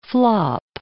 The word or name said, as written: flop